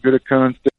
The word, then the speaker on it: Gutekunst: Brian Gutekunst.